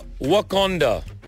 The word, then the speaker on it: Wakanda: I want to go to Wakanda. But Uganda, Endeavor, which date? No, not Uganda. Wakanda. Hmm? Wakanda. Spell it. W hmm. A K A N D A.